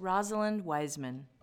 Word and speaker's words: Rosalind Wiseman: said her name wrong, by the way. It's Rosalind Wiseman.